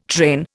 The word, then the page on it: train: Indian